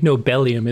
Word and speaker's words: Nobelium: I normally give you crap on pronunciations, but I actually think Nobelium is spot on.